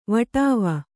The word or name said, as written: ವಾಢಾವಾ